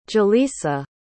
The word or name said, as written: jalisa